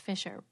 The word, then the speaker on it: Fisers: And her last name is spelled F-I-S-E-R-S, even though it's pronounced Fisher, Bernadette Fisher.